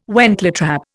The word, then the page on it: wentletrap: Indian